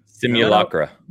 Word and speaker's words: Simulacra: Simulacra. – No, you say those L's. That's English. Right, I say that L.